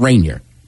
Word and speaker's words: Rainier: Moved to Seattle, and it took me probably about a year to stop saying Rainier. Because it's spelled the same as I said it. Yeah. Like, man, Rainier.